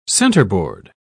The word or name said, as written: centerboard